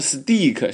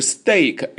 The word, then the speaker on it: steak: 那么 steak, 注意发音啊，不要念成 steak, 是 steak, A 的发音， EA 发成 A。